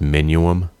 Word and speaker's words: Minuum: I think it's pronounced Minuum, like continuum, but mini.